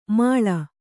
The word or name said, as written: ಮೇಳಿ